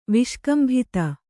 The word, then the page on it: ವಿಷ್ಕಂಭಿತ: Kannada